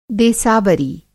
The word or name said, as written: देसावरी